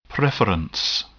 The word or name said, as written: preference